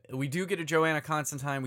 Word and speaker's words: Constantine: And yes, the actual pronunciation is Constantine. Get used to it. Gaiman and Alan Moore have corrected us all. It's Constantine.